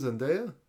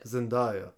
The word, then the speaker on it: Zendaya: Her name's Zendaya? – Zendaya. – Okay, my bad. I said it wrong.